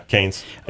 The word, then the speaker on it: Cannes: The Cannes Film Festival. Am I saying that right? – Yeah, Cannes. Yeah.